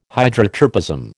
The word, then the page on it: Hydrotropism: American English